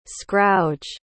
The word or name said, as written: scrouge